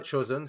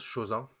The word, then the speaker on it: Chosen: Par nous contre, avons ça, donc ça se prononce pas Chosen, ça se prononce Chosen.